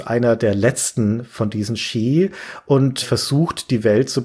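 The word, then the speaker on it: Sidhe: Shi spricht man das aus. – Shi spricht man das aus? – Ja, das ist ja ein Originalname aus der keltischen Mythologie.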